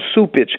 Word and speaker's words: Cupich: C-U-P-I-C-H, mais on prononce Soupitch.